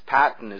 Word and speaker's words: Peyton: Well, I tend to butcher names, and I butchered my own because Patton is spelled Peyton, but we pronounce my name wrong,